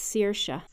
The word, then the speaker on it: Saoirse: S-A-O-I-R-S-E. Do you know how to pronounce that? – I do. It is an Irish name, and it's pronounced Saoirse. – Saoirse.